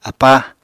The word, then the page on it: apáa: Apatani